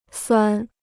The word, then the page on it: 酸: suān